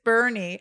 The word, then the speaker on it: Boerne: I mean, again, it's spelled Boerne. Sounds like Ernie. And it's Boerne.